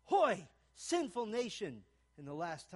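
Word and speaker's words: hoy (הוֹי): It's pronounced in Hebrew, "hoy."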